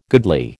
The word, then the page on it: goodly: American English